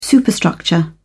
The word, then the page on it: superstructure: British English